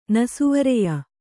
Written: ನಸುವರೆಯ

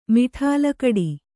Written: ಮಡಿಲುಗಟ್ಟು

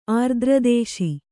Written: ಆರ್ದ್ರದೇಶಿ

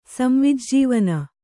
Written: ಸಂವಿಜ್ಜೀವನ

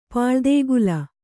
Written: ಪುಲಿದೊಗಲು

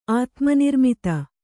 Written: ಆತ್ಮನಿರ್ಮಿತ

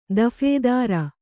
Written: ದಫೇದಾರ

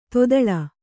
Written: ತೊದಳ್

